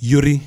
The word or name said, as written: Yuri